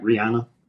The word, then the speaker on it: Rihanna: Her actual name, how you pronounce it, is Rihanna. – Oh, well, all right, Rihanna.